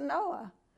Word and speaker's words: NOAA: talking about cruise ships here, a point of interest here, the system that navigates a big ship into safe harbor is called NOAA. As in the days of Noah. It's spelt different, but it is Noah.